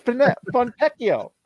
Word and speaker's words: Fontecchio: Is it Fontecchio or Fontecchio? – I am not an Italian speaker, but I know that Pinocchio is pronounced. – Pinocchio. Oh, that's true. Let's talk Fontecchio. like Pinocchio, Fontecchio.